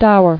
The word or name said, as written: Stour